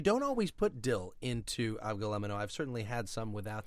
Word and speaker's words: avgolemono: This is an avgolemono.